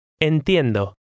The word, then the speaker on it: entiendo: Now try number 6, keeping in mind that each vowel sound indicates a separate syllable. – Entiendo.